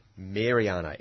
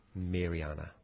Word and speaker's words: Maireana: Mariana, M-A-I-R-E-A-N-A. Mariana sedifolia and sedifolia S. Spell that again, please. – M A I R E A N A. Mariana.